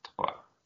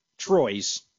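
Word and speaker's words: Troyes: It's I think it's pronounced the same way as they say three. Ah, Twa. Yeah. Troy's.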